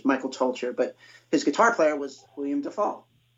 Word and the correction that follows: Duvall: William Duvall, yeah.